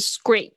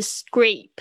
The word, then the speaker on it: scrape: 好，接下来两个就是我刚才在讲故事的时候有讲到的，就是两个东西，一个是 scrape。注意一下，它的发音是 a 啊，a 有夹住夹了一个字母，然后 e 这样子的组合，通常都是发 a 这个双元音 a e a，所以是 scrape，不要念成 scrap 啊，也不要念成 scrape，scrape 发音的时候，你发现你的嘴巴这个变变扁扁的 scrape，然后接下来是 dent。